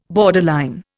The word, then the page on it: borderline: Indian